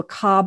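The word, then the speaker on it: Ka: they call the soul the car body, spelt K-A, pronounced car, as in motor car, the okay.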